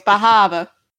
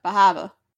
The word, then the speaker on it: Bar Harbor: You said it wrong. – No, fine. Bahaba. – Bahaba. – Bahaba. No, you're all wrong. It's Bahaba. – Bahaba. – Yeah, Bahaba.